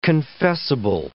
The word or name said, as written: confessable